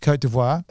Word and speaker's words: Côte d'Ivoire: It's Côte d'Ivoire.